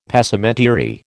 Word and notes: Passementerie: American English

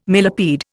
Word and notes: millipede: Indian

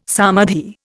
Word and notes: samadhi: Indian

